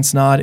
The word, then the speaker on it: Snaadt: My name is Ryan Snod. It rhymes with odd.